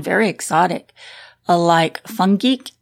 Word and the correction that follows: fenugreek: is that how you say that? Did I pronounce that right? – Oh, fenugreek. – Oh, okay. All right then, fenugreek.